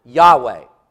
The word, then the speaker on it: Yahweh: That's what we think that the name of God was pronounced like, is what we would pronounce as Yahweh, which is the Hebrew verb to be.